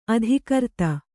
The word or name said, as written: ಅಧಿಕೃತ